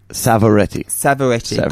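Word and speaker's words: Savoretti: Did I say it right? – Yeah, you did. Close. It's a tough one. – Jack Sovietti. – Savaretti. – Got it. – Yeah. People mix it up with Pavarotti.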